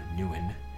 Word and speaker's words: Nguyen: Find Dylan Nguyen. Yeah, not gonna try to pronounce my name the actual Vietnamese way because I've tried teaching people. It's not fun.